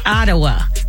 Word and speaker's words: Ottawa: Ottawa.